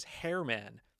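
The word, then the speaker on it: Germán Márquez: And I've always kind of said like Herman Marquez. If you look at it closely, it actually says Hairman. Specifically says Herman Marquez, so that's what I'm going to be calling him for the rest of the year.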